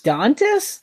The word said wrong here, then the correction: Domantas: Damantas. Damantas Sabonis. – Domantas. – Damantas Sabonis. – Yeah, someday you'll get it.